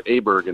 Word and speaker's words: Åberg: You know, Ludvig Åberg, which is known as Aberg in the United States, by mm-hmm.